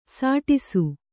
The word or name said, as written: ಸುಡಿಸು